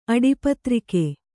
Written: ಅಡಿಪತ್ರಿಕೆ

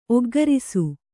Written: ಒಗ್ಘರಿಸು

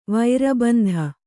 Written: ವೈರಬಂಧ

